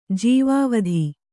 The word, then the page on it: ಜೀವವಧೆ: jīva vadhe